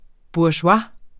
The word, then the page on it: bourgeois: buɐ̯ˈʃwɑ; buɐ̯ɕoˈɑ